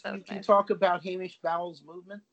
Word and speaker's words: Bowles: Bowles. – Yeah. Bowles.